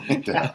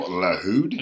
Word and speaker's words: Lahoud: No, it's not Michael Landau. I believe it's Michael Lahoud. – Lahoud.